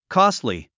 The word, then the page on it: costly: kɔ́stli; コストリィ